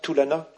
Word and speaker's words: Tulana: Aloysius Pieris habite et travaille près de Colombo, centre Tulana, on prononce comme ça, Tulana, un centre de dialogue entre chrétiens et bouddhistes.